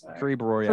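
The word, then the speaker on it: Caribe Royale: Carib how do you pronounce that? – Carib uh, Royale. – Carib Royale. – Carib Royale. Thank you.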